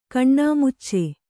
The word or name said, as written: ಕಣ್ಣಾಮುಚ್ಚಿ